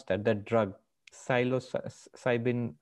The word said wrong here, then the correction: psilocybin: or something. – Psilocybin, yeah.